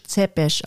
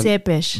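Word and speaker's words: Țepeș: den Beinamen hatte Vlad Tepes, was so viel heißt wie Vlad der Pfähler. – Ja, spricht man aber auf Rumänisch Zepes aus. Vlad ähm, Zepes. Vlad Zepes von mir aus.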